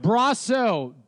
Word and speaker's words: Brosseau: Brasso, B R A H dash S O, Mike Brosseau.